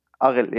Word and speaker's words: Arild: And Arul Is that how I pronounce his name? Arild? – Arild, yeah.